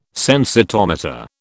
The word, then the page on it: sensitometer: British English